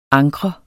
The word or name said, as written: ankre